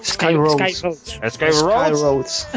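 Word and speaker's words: SkyRoads: Y bueno, David, que antes de entrar al top, entramos en unos pocos juegos que hay que comentar: Stone Island, Ski Roads, no sé muy bien cómo se pronunciaría este, sería... – Sky Roads.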